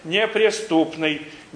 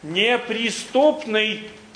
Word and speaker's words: неприступный: Надо грамотно произносить, слышу многие, неприступный, неверно, неприступный. Будьте любезны говорить правильно.